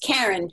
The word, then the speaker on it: Karen: So my website is simply, it's just Karen, K-A-R-E-N-K-E-N-N-E-Y.